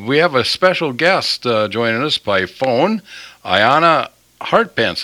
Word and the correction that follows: Anya: Anya. Okay, Anya. That's a beautiful name. How do you spell that? – That's A-N-Y-A.